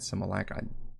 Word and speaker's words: simulacrum: Simulacrum. How do you say it? Simulacrum. Yeah, simulacrum.